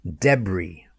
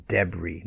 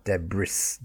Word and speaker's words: debris: Debris, when the house falls down, debris, not debris, debris, D-E-B-R-I-S. Silent S's.